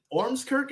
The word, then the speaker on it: Ormskirk: he is in the UK in Ormskirk. Is that a correct pronunciation? Yeah.